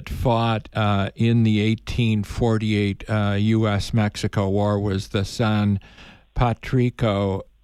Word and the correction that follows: San Patricio: I guess I mispronounced it, battalion, which in Spanish would be St. Patrick Battalion. – Oh, Battalion San Patricio.